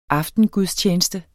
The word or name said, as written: AFTENGUDSTJENESTEN